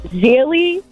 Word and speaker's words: Gigli: It's Gigli, or it's spelled Gigli, G-I-G-L-I, but I think it's Gigli.